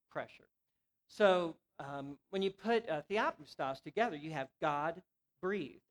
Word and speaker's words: theopneustos: First of all, the word pronounced in its original language is theopneustos.